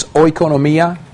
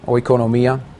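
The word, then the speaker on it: oikonomia (οἰκονομία): Dispensation, the Greek word behind it, you got it in your notes, okay, is oikonomia. Just pronouncing that word, it ought to sound very similar to the word that we use today, economy. That's the same root word as our word economy, oikonomia.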